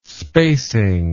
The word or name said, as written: spacing